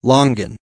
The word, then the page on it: longan: American English